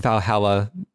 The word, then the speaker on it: VA-11 Hall-A: apparently, this is spelled VA-11 Hall-A. So it's if you just search for Valhalla, maybe the algorithms would be smart enough to put you in the right direction.